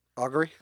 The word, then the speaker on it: augury: Um, and lastly is if I'm saying it right, ergery Augury? – Augury, okay.